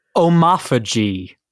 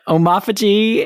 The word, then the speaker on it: omophagy: Emily, so your word today is omophagy. – Oh, God. – Omophagy is spelled O-M-O-P-H-A-G-Y.